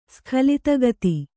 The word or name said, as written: ಸ್ಖಲಿತಗತಿ